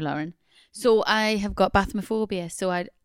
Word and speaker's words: bathmophobia: Bathmophobia, pronounced as it sounds.